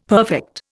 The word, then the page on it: perfect: Indian